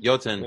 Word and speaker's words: Jotun: Iotan? – Iotan? Is that like Jotun? – Jotun. – Yeah. That's like the Frost Giants, right? – Yeah, think of the Jotunheim from Thor. So.